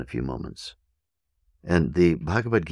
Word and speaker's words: Bhagavad: Bhagavad is spelled B-H-A-G-A-V-A-D. Bhagavad, Bhagavad, Bhagavad.